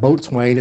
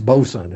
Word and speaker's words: boatswain: And you know, and it's not boatswain, it's bosun, and things like that.